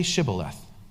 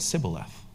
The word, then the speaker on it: Shibboleth: When he said, 'No,' they said, 'Okay, say Shibboleth.' And he said, 'Sibboleth,' for he could not pronounce it right.